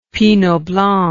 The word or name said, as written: pinot blanc